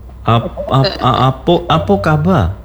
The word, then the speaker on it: Apo kaba: Kalau bahasa Minang kalau O akhir A akhirannya dibacanya O. – Apo kaba?